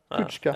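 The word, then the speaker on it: Kucka: eh, nu vet jag inte om jag uttalar det rätt men Kuska eh, Kutschka. – Ja, Kutschka, ah.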